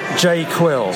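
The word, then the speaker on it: J'Quille: And How do you pronounce the name of the character that is on I pronounce it Jay Quill.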